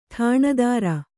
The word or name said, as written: ಠಾಣದಾರ